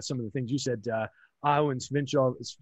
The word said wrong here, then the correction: Svechnikov: I can never say his name. Svechnikov, thank you.